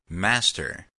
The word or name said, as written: master